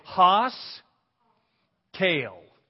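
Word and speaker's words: haskel: Two syllables, Haas, you know, like the big guy on, what was that TV show? Bonanza. Okay, Haas, Kale, Haas Kale.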